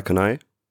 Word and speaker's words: Kani: So, so wie ich den Namen ausgesprochen habe, kennt ihr denn die Marke auch so oder kennt ihr das unter einem anderen Namen? Karl Kanai.